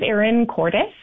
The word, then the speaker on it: Cordes: My name is Erin Cordes, spelled like Curtis, Ontario, if you're familiar.